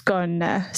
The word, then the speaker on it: Skåne: So it's spelled S-K-A-N-E, but it's actually pronounced Skåne. So I actually say it's like scone, but with a little accent on the E.